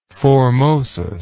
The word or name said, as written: formosus